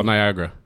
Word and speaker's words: Niagara: Ja, Niagara.